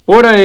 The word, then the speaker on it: pora: Los pora es, con un acento en la O, significa fantasma, el ánima, un duende.